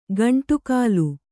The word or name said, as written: ಗುಂಡಕಲ್ಲು